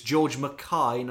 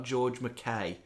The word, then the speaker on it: George MacKay: It's George Mackay, not George McKay. Even though it's spelt McKay, it said Mackay.